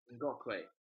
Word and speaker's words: Ngakoue: Ngakoue.